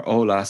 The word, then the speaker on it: hola: Subject Hola, or Hola, sorry, I know the H is silent.